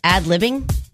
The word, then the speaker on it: ad-libbing: But he's really good at adding lib and stuff like that. Did I say that right? Ad-libbing? – Yeah, adding lib.